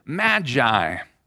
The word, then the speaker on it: magi: The Greek term there is magoi. We typically pronounce it magi.